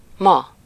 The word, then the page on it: actuellement: France; /ak.tɥɛl.ˈmɑ̃/